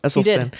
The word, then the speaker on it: Esselstyn: I think I pronounced his name right, though. Esselstyn. – You did.